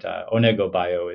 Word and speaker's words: Onego Bio: Actually, it's pronounced in Finnish. They pronounce every letter. So yes, of course, sometimes people do say Onego Bio, but you know. That's fine to me too, but Onego Bio is the correct pronunciation, and it doesn't actually mean anything.